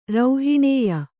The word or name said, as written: ರೌಹಿಣೇಯ